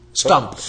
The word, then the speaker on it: Stumpf: And one of the things I was wondering about as well, you're talking about how, and how do you say his name? Peter Stumpf? – Stumpf. S-T-U-M-P-F. Stumpf. – All right, so. – I guess. Stumpf. If I was German, I would say Stumpf.